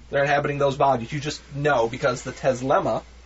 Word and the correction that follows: Telesma: Did I say it right? – No. – Telesma. Because the Telesma lets you know inherently.